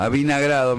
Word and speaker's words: avinagrar: Esa cosa de, de a, uy, avigranado. – A, avinagrar. me, se me confundió la sílaba.